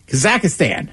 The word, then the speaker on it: Kazakhstan: Kazakhstan. Mm-hmm. Am I saying that correctly? – Yes. – Kazakhstan.